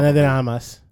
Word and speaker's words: Ana de Armas: Which is a newest release from Netflix itself, starring none other than Ryan Gosling, Ana de Armas. How do he pronounce it? I feel like I want to try. Ana de Armas.